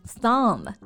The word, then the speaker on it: thumb: 总结一下呢，就是当字母 b 在 m 之后呢，它就不发音了。类似的词还有很多，like 大拇指 thumb，梳子怎么说，贝贝？comb，哎，对，水管工。